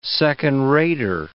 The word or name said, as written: second-rater